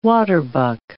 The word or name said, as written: waterbuck